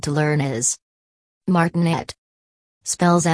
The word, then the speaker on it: martinet: Pronounce martinet with the accent on the last syllable, martinet.